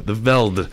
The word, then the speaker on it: The Veldt: The, like T-H-E. Veldt. V-E-L-D-T. – I think it's just called The Veld. – The Veld. The Veldt. – Silent T.